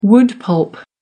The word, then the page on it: wood pulp: American English